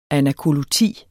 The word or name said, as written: anakoluti